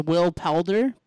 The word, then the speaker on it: Poulter: It's Will Poulter. P-O-U-L-T-E-R.